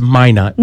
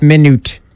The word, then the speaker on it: Minot: It's Minot.